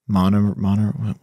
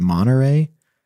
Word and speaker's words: Monterey: Somebody told me there's no T. They said, just said you pronounce the, you don't pronounce the T. So it's Monterey.